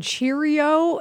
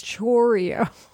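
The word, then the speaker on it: Chourio: I love that it's Kitty and people are, it's not you, but people keep calling him Jackson Cheerio. And I'm like, it's Chorio. – It is? – Well, it's C-H-O-U. It's not. – I've not heard anybody pronounce the name Chorio. – Just heard Cheerio. It's more Chorio, but like, it's not Cheerio.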